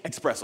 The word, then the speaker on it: espresso: Even in the way it's spelled espresso, we say espresso. Let me get an espresso, please. I'll take a tall espresso. That's the way we say it. This is like a direct quote from Inc. magazine right here. So I guess Europeans say it correctly, so we need to say it correctly as well.